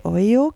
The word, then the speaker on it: Olho: Es heißt Canto de Oyo, glaube ich. So spricht man es richtig aus, hoffe ich. Oyo schreibt man O-L-H-O.